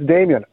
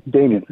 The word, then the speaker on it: Damjan: Listen, I wanted to make sure I respected the pronunciation in your name, because you spell it D-A-M-J-A-N, but it's pronounced Damian. – Yeah, no, it's Damian. People love to pronounce it Damjan. It's like very, very much. That's what people want to pronounce it. It's like Django, but the J is silent, and it sounds like an I.